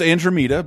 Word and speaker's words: Andromeda: It's pronounced Andromeda, but I'll accept that, Kat.